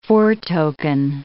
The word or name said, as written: foretoken